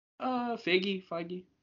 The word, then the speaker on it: Feige: Is that how you pronounce his name? I keep forgetting. – Feige. Yeah. Okay.